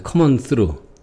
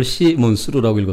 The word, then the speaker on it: Come on through: 네, Come on through. 저는 이걸 시몬 스루라고 읽었었는데 찾아보니까 Come on through네요.